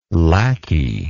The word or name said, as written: lacquey